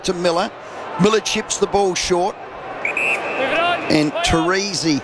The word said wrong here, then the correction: Trezise: how do you say this, Bruce? – Trezise.